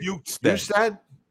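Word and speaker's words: Bjugstad: Bukestad.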